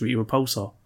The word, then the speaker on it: Rita Repulsa: I mean, I'll still play it a bit because you can unlock Elizabeth Banks, Rita Repulsar. I haven't unlocked her yet, and I want Rita Repulsar. – We're both so tired today. – That's how you pronounce her name. It's Rita Repulsar.